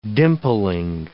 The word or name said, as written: dimpling